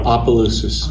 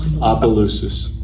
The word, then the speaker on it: Opelousas: Opelousas. Opelousas.